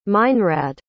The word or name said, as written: meinrad